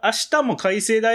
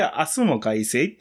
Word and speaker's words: 明日も改正: その、あ、明日も改正だよっていうようなイントネーションではなくて、明日も改正っていう、えー、ちょっとこう平凡なイントネーションでやるのが正しいはずなんだが、起死回生の明日も改正、起死回生も明日も改正、どうも改正です、みたいな、もが多い時があるよね。